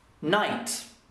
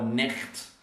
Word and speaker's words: night: Night is spelled N-I-G-H-T, and we don't say the G or the H, both are silent. So it is the silent letters are G and H, because we say night, night.